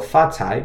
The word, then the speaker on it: 发财: The next part of 恭喜发财 is 发财, so that's spelled F-A in the first tone, fa, and then C-A-I in the second tone, cai. Remember, C in pinyin makes a kind of T-S, ts sound. So fa cai means to acquire wealth.